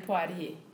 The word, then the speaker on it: Poirier: Jehanne Poirier.